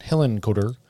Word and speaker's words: Hillenkoetter: I don't know how to say it, but Hillenkoetter. – Yeah, it sounds right.